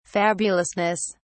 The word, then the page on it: fabulousness: British English